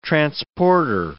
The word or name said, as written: transporter